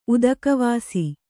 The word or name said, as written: ಉದಕವಾಸಿ